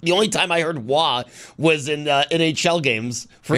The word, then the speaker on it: Roy: Like Derek Roy, I guess he said at some point that it was Wah, like the goalie. That, like, that is the actual pronunciation of his name. – Oh, really? – But he just, you know, it was like Roy and you roll with it.